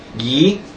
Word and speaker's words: Yeh: Y-E-H. How would you say that? Yi? Yeah, there we go.